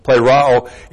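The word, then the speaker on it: plerao (πληρόω): Plerao, looks like this in the Greek. P-L, long E-R-O, long O, plerao,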